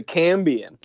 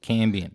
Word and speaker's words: Cambian: Gambian. Is that correct? Is that how you say it? – The Cambian. – Cambian. – With a C.